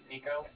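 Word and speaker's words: Niko: My name's Nico, that's N-I-K-O, and the Bank of Cardiff is spelled C-A-R-D-O-K-S-T-O-R-D-O-K-S, Frank, that's a car and the different, and my address line is 858-779.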